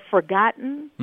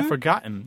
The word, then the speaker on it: forgotten: or forgotten, mm-hmm. – I don't pronounce the T. – Mm-hmm. Mm-hmm. Yeah, forgotten.